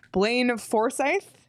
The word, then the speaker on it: Blaine Forsythe: But the Coyotes have added to their coaching staff Blaine Forsythe.